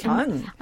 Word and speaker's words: Jeon: Is it Jeon? It's Jeon.